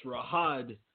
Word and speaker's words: Riyadh: I butchered that last time. It's Riyadh, Saudi Arabia.